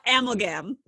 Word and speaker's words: Amalgam: Our podcast title is, I don't know if I'm pronouncing it right. – Amalgam. – Yes. Okay.